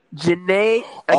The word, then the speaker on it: Jhené Aiko: Jhené Aiko.